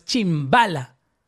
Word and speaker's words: Chimbala: Voy a saludar a Chimbala. Tiene tilde en, acento en la primera A, ¿correcto? – Sí, porque Chimbala, Si, no lo sabes, pues lo sabes, puedes decir de otra manera.